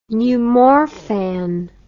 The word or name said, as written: Numorphan